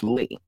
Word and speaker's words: Lee: So we all remember the Danny Lee, or I say this Danny Lee. I'm not saying Lay because it's Lee. It's spelled Lee.